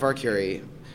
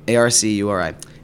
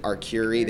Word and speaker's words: Arcuri: My name is Jeff Arcuri, Arcuri, and everyone mispronounces it Arcuri. They say Arcuri.